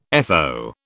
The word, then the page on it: FO: British English